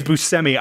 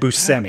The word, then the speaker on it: Buscemi: It is Buscemi. – I watched an episode of The Simpsons that he was in, and he pronounced his own name in it, and he said Buscemi.